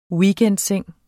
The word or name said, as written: WEEKENDSENG